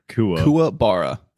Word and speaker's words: Kuwabara: I loved how Karabura, I'm pronouncing that right, correct? – No. – Karubane? – Kuwabara. – Kuwabara. – I've definitely mispronounced it too. – At least that's how I pronounce it. – I keep thinking it's K-U-R-A, not K-U.